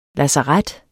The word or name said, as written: lazaret